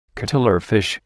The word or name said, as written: cuttlefish